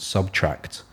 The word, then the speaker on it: SBTRKT: this group out of the UK called Subtract.